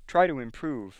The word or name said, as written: prove